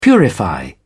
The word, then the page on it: purify: British English